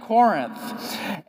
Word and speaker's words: Corinth: I'm a little bit nervous about talking about 1 and 2 Corinthians because for the past 5 weeks, I have been butchering the name of the city, Corinth. Did I say it right this time? Corinth. Every single Sunday for the last 5 weeks, I have gone home and my wife has looked at me and said, I love you. Here it comes. You know, like that's a good intro. Hey, I want you to know I love you. Here we go. I love you, but you're saying Corinth wrong.